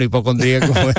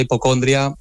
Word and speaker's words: hipocondría: A ver, para mí oficialmente es hipocondría, con acento en la I. – Exacto. – Pero me he encontrado mucha gente que lo llama hipocondria y bueno, nos entendemos.